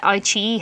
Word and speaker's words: iQIYI: And then there's iQIYI, and that's spelled I-Q-I-Y-I, but it's read iQIYI, which has actually also started to carry some Korean dramas on there.